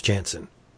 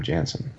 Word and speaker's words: Janson: Or Janson. We've been pronouncing it wrong. – Wait, have we really? – Yeah, it's Janson. Yeah, it's Klaus Janson.